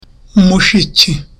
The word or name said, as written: mushiki